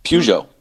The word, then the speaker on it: Piaget: Is it pronounced Pagot? P-I-G-U-E-T? – Peugeot.